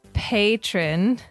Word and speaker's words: patron: We finally have our first patron. Said it right? – Yeah. – Patron? – Yeah, it's not the person is not a Patreon. – You're a patron. – That's the platform.